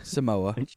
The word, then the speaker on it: Samoa: Goes to the University of American Sonoma. – Samoa. – Samoa, sorry. Yeah, I wrote it down right. I just said it wrong.